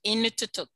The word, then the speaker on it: Inuktitut: If they spoke, you're going to pronounce this for me, Inuktitut. – Inuktitut. – Right, Inuktitut. – Voila.